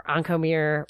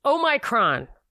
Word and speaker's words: Omicron: So also, and people keep saying it wrong, keep saying Omicron. Or Oncomere, or Moronic. Yeah. It's Omicron.